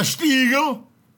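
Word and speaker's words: Stiegl: A Stiegel. And you have to say it like that, too. – A Stiegel. – Stiegel.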